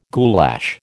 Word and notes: goulash: American English

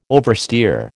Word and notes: oversteer: American English

